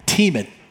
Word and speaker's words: Thieman: Again, my name is Mo Thieman, and my last name is pronounced Thieman, by the way. We've got a little bit of confusion on that. I don't know why. I've been here for a while. Thieman. That's how you say the last name.